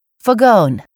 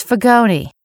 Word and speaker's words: Fagone: You've been hearing me say, Fagone. Guess what? It's Fagone, with a long E.